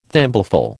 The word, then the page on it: thimbleful: American English